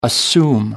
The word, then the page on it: assume: American English